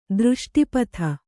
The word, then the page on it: ದೃಷ್ಟಿಪಾತ: Kannada